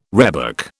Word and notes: rhebok: British English

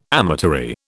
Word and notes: amatory: British English

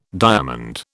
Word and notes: diamond: British English

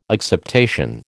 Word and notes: acceptation: American English

